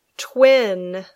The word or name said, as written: twin